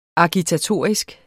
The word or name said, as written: agitatorisk